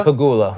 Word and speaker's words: Pegula: Pegula. How do you say it? Pagula? – Pagula. – Pagula.